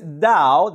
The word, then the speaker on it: Tao: with this Tao. They call it a Tao, pronounced with a D, but spelt with a T, T-A-O.